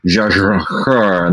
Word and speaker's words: Jia Zhangke: I could do the give my shot at the proper Mandarin pronunciation. It's like Zha Zhangke, and there's a whole thing that you do.